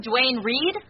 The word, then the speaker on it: Duane Reade: I even bought new pantyhose from Duane Reade. – You mean Dwayne Reed? – Oh, is that how you pronounce it? – Over here.